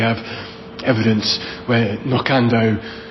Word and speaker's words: Knockando: We don't know why it's called that, but it's also stressed on that second syllable, Knockando, as if it was the Black Hill, it'd be Knockando, is how you pronounce it in English.